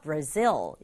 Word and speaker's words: Brazil: 브라질이라는 나라인데 발음이 비슷한 것 같지만 영어는 끝부분에 강조를 줘서 Brazil 이렇게 발음하면 좋겠고요.